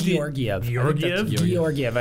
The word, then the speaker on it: Georgiev: I think it's Georgiev. I think it's a hard G.